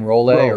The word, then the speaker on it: Roll: How do you pronounce her last name? Roll.